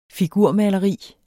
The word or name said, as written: figurmaleri